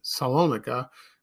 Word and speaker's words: Salonika: Her paternal grandparents were Greek, and they were from Thessaloniki, Salonika. That's how you say it.